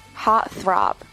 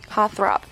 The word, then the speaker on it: heartthrob: 注意一下这里 double T 的读音啊。 Heart throb，美音当中这个 heart 的 t 我们是吃掉的啊。 Heart throb，呃，最后最后这个是 babe，babe 宝贝啊，baby 的更加亲密的一种称呼。